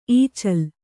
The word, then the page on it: ಈಚಲ: īcala